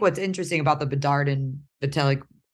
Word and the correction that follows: Fantilli: how do you say his name? Why am I blanking? Fantilli. – Fantilli. That's right.